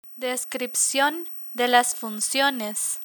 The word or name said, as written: descripcion de las funciones